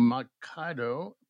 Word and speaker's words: Moncada: Today, we have Pablo Moncada.